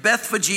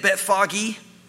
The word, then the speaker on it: Bethphage: They say it Bethphage. So I never know how to say it because that's how they say it in Israel. But Bethphage is how another way it's said.